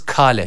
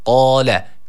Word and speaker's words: قال: Kale şeklinde sıkça okunuyor. Yanlış bir okuyuştur. Dikkat edelim. Kaf harfi var. – قال. – Kale yanlış olur.